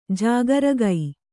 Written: ಜಾಗರಿಗ

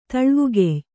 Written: ತುಳುವಗಾಯಿ

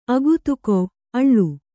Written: ಅಕ್ಕೊತ್ತೆಗೊಳ್

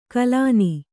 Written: ಕಲನಿ

